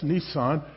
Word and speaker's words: Nisan: So on the 10th day of this month, it's the Hebrew month of Nisan, which is spelled like the Japanese car with one less S, Nisan.